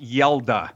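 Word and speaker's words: Hjalte: Uh, the first name though is pronounced Yelda. – Okay, so when you're at your, your, draft party, it, it definitely does not is not spelled like Yelda, but that's that's how you pronounce that first name.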